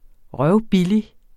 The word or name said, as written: røvbillig